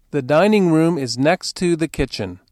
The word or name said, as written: dining room